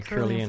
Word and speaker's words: Kirlian: like Kirlian, how do you say that word? – Yeah, Kirlian photography.